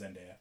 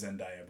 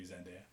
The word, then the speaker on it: Zendaya: I think it's pronounced Zendaya. Everybody says Zendaya, but it's supposed to be Zendaya.